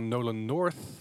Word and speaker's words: Nolan North: Eh, deze show wordt gepresenteerd door Nolan North.